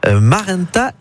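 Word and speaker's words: Maranta: Vous m'avez donné des petits noms de plantes, hein, c'est Marenta et Calathea, est-ce que je prononce bien ? – C'est parfait, c'est parfait.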